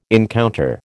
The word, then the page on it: encounter: American English